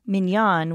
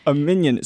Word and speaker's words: minyan: I like to pronounce it minyan, which is how it's pronounced in Hebrew, because then I'm not talking about a little yellow...